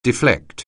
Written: deflect

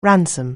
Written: ransom

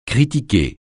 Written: critiquer

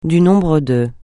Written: du nombre de

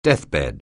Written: deathbed

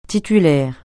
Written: titulaire

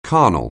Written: carnal

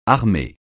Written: armer

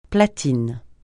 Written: platine

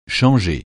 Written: changer

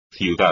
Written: ciudad

